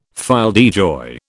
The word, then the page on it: fille de joie: British English